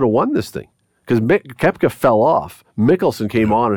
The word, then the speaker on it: Koepka: Can you imagine if Koepka, is how you say? – Yeah, or Mickelson.